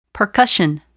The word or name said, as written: percussion